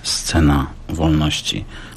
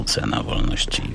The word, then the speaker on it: Scena Wolności: Scena Wolności. Chociaż niektórzy tutaj już zaczęli mówić w Słupsku Cena Wolności, wymawiając tak bezszelestnie to S.